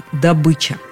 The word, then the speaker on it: добыча: Вообще-то «добыча» звучит именно как «добыча». В орфоэпическом словаре под редакцией Аванесова, в словаре ударения Резниченко «добыча» приводится как единственно верный вариант. По мнению языковедов, у человека, который хочет говорить правильно, только один вариант с ударением на «ы».